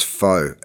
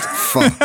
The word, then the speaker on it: pho: Every time I drive past it, I want to walk in and say, it's not pronounced faux. It's pronounced pho.